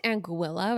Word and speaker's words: Anguilla: It's actually pronounced Anguilla, but that sounds incorrect. So I'm going to keep calling it Anguilla.